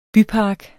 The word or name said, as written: bypark